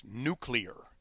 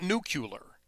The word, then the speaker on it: nuclear: the word is pronounced nuclear, not nuclear, nuclear.